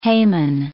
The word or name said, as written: Haman